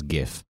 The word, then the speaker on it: GIF: Well, pho is not an English word, but GIF is, and GIF has a correct pronunciation, which is GIF.